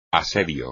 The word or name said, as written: asedio